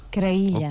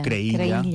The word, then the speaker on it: Creïlla: No, que és Creïlla. – Creïlla. – O Creïlla, pronunciat a la valenciana.